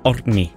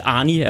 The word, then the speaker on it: Orkney: Hvad var det rigtige? Må jeg lige få den? – Orkney. – Ja. Altså, jeg kender en islænding, der hedder det samme som dig. – Okay. – Jeg vil sige, det hjælper efter fire snaps at udtale det navn. – Orkney. – Ja. Men vi kæmper lidt med den. Arnie er også okay. – Arnie er også fint.